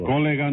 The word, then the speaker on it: colega: Colega no, colega. – ¿Cómo? – Colega, con acento en la E. – ¿Y yo no le puse el acento en la E? – No, señor, usted se lo puso en la O.